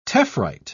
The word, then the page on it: tephrite: British English